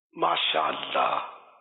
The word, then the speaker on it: ماشاء اللہ: اب ماشاء اللہ بھی صحیح بولنا نہیں آتا نا، مشکل یہ ہے، ماشاء اللہ یہ کر رہے ہوتے ہیں بعض لوگ، یا ماشاء اللہ نہیں ہے نا، ماشاء اللہ صحیح تلفظ تو یہ